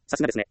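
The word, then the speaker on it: 流石: さすがという漢字はさすがと読みます。さすがですねというときに使うさすがです。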